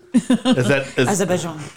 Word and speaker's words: Azerbaijan: Azerbaijan. – Yeah, it was from the, like, former Russian state. – Azerbaijan. – Yeah. – I like saying it like that because Eddie Izzard. – Azerbaijan.